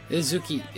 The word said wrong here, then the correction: Izuku Midoriya: Izuku Midoriya.